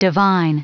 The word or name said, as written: divine